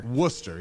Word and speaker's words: Worcester: Worcester, yes. – Worcester.